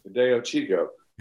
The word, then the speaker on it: Fideo Chico: Now here's a guy at the other end of the spectrum, and am I pronouncing it correctly? Fideo Chico? – Fideo Chico. Yeah, which means little sausage or something.